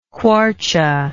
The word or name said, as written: kwartje